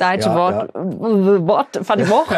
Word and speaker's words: Deutsche Wort für die Woche: Du hast nämlich eine Serie, die heißt Deutsch Wort für die Woche. Ist das richtig ausgesprochen? – Deutsche Wort für die Woche. – Deutsche Wort für die Woche, gar nicht so einfach.